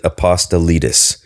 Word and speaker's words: Apostolides: I'm Greek and Italian. So actually we pronounce it Apostolides.